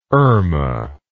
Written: irma

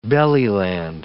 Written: belly-land

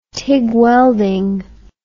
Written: tig welding

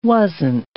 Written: wasn't

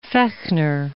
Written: Fechner

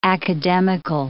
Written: academical

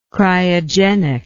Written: cryogenist

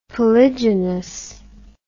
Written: polygenous

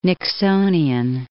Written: Nixonian

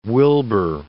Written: Wilbur

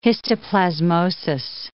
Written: histoplasmosis

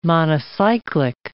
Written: monocyclic

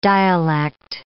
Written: dialect

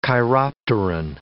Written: chiropteran

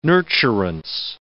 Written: nurturance

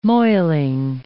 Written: moiling

